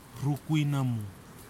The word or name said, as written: rukuinamu